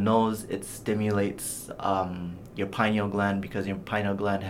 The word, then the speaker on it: pineal: It stimulates blood flow to the pineal gland. Pineal, phonetically, is very similar similar to penile.